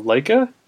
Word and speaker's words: Laika: So Paranorman is the new stop action animated film from Laika. I'm pronouncing that correctly, right? – You are, yes.